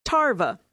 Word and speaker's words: Tarva: That's M-A-L-M, Malm. Okay. What about Tarva? Rhymes with larva, but the name is Tarva. Stefan. Okay, that sounds pretty normal. Stefan, S-T-E-F-A-N.